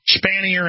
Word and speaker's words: Spanier: S-P-A-N-I-E-R. Is that how you pronounce that, guys? Spanier, yeah. Was faulted in an internal Penn State report after former assistant football coach Jerry Sandusky was convicted of child molestation.